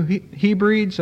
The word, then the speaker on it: Hebrides: Patton, who was a missionary to New Hebrides, I believe is the way you pronounce it, H-E-B-R-I-D-E-S.